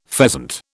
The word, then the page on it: pheasant: British English